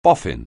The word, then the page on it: boffin: anglais